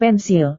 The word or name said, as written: pensil